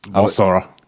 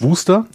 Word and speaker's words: Worcester: Worcester. Ja. – Worcester. – Aber ich glaube, du hast das voll und ganz richtig gemacht. Ist es Wooster, ja? Ich glaube schon.